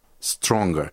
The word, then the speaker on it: stronger: Stronger. Кстати, здесь вот это «г» произносится.